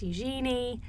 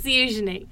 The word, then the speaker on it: Eugenie: Before I started covering the Royal Beat, I thought it was Eugenie. It's not. It's Eugenie. That's the correct pronunciation.